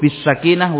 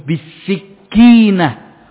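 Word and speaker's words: sakinah: Alaikum bisakinah wa waqar. Dan juga wibawa. Tidak kerusak-kerusuk, tidak leda-ledi, dan seterusnya, kata Rasulullah SAW. Ada yang bacanya salah. Bagaimana bacanya? Alaikum bisikinah. Wajib kalian membawa pisau.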